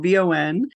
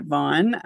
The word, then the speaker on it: VON: We internally pronounce it VON, not Vaughn, which is something that I hear a lot from people.